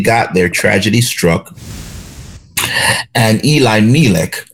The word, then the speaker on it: Elimelech: or Eli Melech. When I spoke with an Orthodox Jew, and he said the proper pronunciation is Eli Melech.